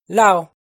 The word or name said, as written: Lauj